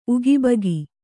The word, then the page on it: ಉಗಾಭೋಗ: Kannada; ugābhōga